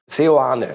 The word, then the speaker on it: Seoane: Swan, richtig? Aus Bern? Seoane. Seoane.